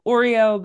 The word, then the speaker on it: Awreeoh: It's called Oreo, but it's spelled AWREEOH. – It looks like Oreo, like Oreo Speedwagon.